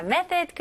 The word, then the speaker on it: method: 발음은요, 여기서 th 발음을 잘 해주셔야 되겠죠? method, okay? th 발음이 아직도 어렵게 느껴지시면 살짝 메롱으로 혀가 조금 나와야 돼요. 자, 제가 천천히 해 드릴게요. method, method, okay? 그래서 살짝 메롱이고 거기다가 바람이 솔솔솔솔 들어가는 느낌이 있어야 돼요.